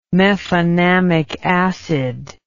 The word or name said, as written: mefenamic acid